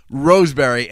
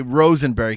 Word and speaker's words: Roseberry: This is Paul Roseberry, and I made it a point to not say Rosenberry because his name gets mispronounced tons of time.